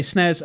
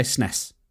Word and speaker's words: SNES: British people say snares, Americans say SNES.